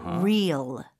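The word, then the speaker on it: Reels: Real, real. – 어, 스펠링이 어떻게 되나요? – R-E-E-L. 이거 R-E-A-L 하고 발음이 똑같아요.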